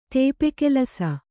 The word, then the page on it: ತೇಪೆಕೆಲಸ: tēpe kelasa; Kannada